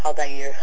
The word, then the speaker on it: Altair: Yeah, he pronounces differently than me. It's Altair.